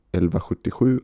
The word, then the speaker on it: 1177: es gibt eben auch eine Webseite, die 1177.se heißt, El Vajutejö auf Schwedisch wird das ausgesprochen. Und das ist so der zentrale Zugangspunkt zum Gesundheitswesen.